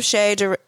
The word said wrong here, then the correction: Che Durena: I keep saying his name wrong. Next week, we have Che Durena.